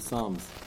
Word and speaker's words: Psalms: Somebody have to asked me yesterday, he said he had some uh, Akum in the office who are very into Psalms. I don't know why they put a silent P there, but that's what they're into.